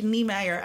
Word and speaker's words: Nijmeijer: Want toen gingen jullie heel erg bedenken over Niemeyer, Nijmeijer. Maar volgens mij schrijf je Nijmeijer, maar spreek je Niemeyer uit.